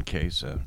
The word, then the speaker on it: Case: My name's John Case, just spelled like case of beer.